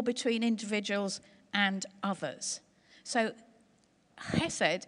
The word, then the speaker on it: hesed: There's a kind of sound at the beginning that I can't do, but it's kind of hesed, okay? But it, it's when you transliterate it, it begins with an H.